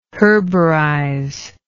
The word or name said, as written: herborize